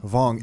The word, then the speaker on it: Vuong: John, how do I pronounce your last name? – Vuong. – Vuong. – The that U is silent.